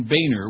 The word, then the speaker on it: Boehner: Oh, and the, speaking of John Boehner, which is how you pronounce his name, my bad yesterday.